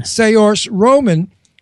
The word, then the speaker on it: Saoirse Ronan: Saoirse Ronan, couldn't resist him.